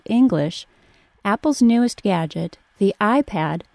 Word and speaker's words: iPad: The words iPad and iPod are identical except that iPad is pronounced with a short A and iPod is pronounced with a short O.